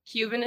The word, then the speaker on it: cumin: Cumin is great? – Cumin. – Oh. – it's spelled like cumin, but it's cumin.